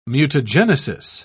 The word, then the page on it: mutagenesis: British English